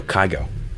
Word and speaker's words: Kygo: K-Y-G-O. Kygo.